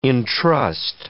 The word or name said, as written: intrust